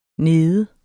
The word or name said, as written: nede